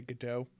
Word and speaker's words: Gadot: starring Gal Gadot. – I know. Got it. – I think the proper way to do it is pronounce the T, even though I want to say Gadot. It's Gadot, I think.